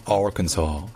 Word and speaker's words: Arkansas: Dans Kansas, en fait, l'accent tonique est sur le can, donc c'est Kansas. Alors que dans Arkansas, l'accent tonique est sur le A, donc c'est Arkansas, Arkansas. Et donc le KN dans l'Arkansas, il n'est pas du tout accentué. C'est juste un KN. En fait, on n'entend presque pas la voyelle.